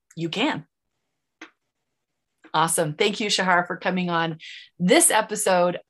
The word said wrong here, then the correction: Shara: You said my name wrong again. Shara. Shara. Like Sarah, but Shara. – Yeah. Shara, thank you for coming and sharing on this episode of Share Your Hotness.